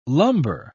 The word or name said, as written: lumbar